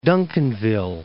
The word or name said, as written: Duncanville